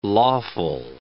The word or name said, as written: lawful